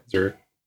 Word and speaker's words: Zurich: I said that right, Zurich? – Yeah, Zurich.